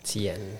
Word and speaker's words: Dzyan: Según una leyenda, aparecía en el sánscrito de Sian Se escribe D-Z-Y-A-N. – Cian, sí.